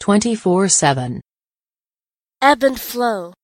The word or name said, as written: twenty-four-seven